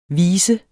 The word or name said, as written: visens